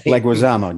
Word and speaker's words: Leguizamo: Leguizamo, John Leguizamo.